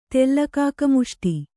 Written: ತೆಲ್ಲಕಾಕಮುಷ್ಟಿ

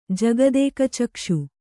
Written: ಜಗದೇಕಚಕ್ಷು

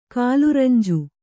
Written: ಕಾಲುರಂಜು

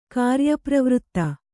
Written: ಕಾರ್ಯಪ್ರವೃತ್ತ

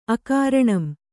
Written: ಅಕಾರಣಂ

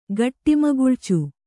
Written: ಘಟ್ಟಿಮಗುಳ್ಚು

